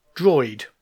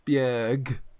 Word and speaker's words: Borg: But Droid is spelt in Swedish B-O-R-G. – Yeah, it's not. My wife pronounced it for me properly, and it's something like Bjerg or something like that.